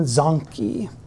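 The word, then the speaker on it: Zanchi: His name was Jerome Zonkey. I know, funny name, it rhymes with donkey.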